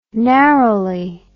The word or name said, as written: narrowly